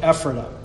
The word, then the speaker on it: Ephrata: Okay, you might say Ephrata or something else like that, fine. But in Lancaster County, they call it Ephrata, okay?